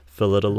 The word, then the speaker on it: Phyllida: And then they bring on director Phyllida Lloyd. – Yep. – That's how you say her name, right? – Yep, Phyllida. – Phyllida? – Mm-hmm.